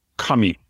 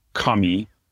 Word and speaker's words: kami: And another word, kami, also means God. So paper and God are pronounced the same way. The word for both of those in Japanese is kami.